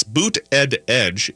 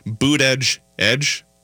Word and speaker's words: Buttigieg: Locally, Mayor Pete Buttigieg, I've finally learned how to pronounce his name. It's Buttigieg, if you can't really say it very well, or I kind of think of it as saying Buttigieg.